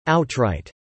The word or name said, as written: outright